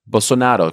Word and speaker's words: Bolsonaro: Political leanings aside, it's impossible to deny that Amazon deforestation under Bolsonaro, did I say that correctly? – Bolsonaro, excuse me, I gave it a little Italian twist there, rose to historic highs and indigenous land rights dipped to historic lows.